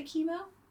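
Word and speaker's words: Kimo: Maybe it's pronounced chymo. – I would say chemo. – I would say chemo too because I did.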